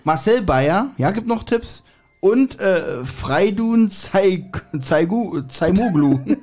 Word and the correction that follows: Feridun: Erstmal steht da Feridun. – Ja, Feridun, ja, Feridun Zaimoglu.